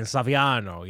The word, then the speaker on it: Saviano: Brian, yes, Saviano? Absolutely correctly. It's a little Italian. Saviano, yes.